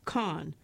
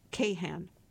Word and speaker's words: Kahn: To be well-versed in Kahnology, you should know that it's pronounced Kahn, not Kahan. I just say one of the A's is silent, Kahn says. You can choose which one.